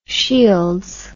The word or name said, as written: shields